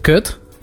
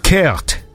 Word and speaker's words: kut: Wait, so how do you say it again? – Kut. – Kurt. Like that? – Kut. – Kut. – It's spelled K-U-T.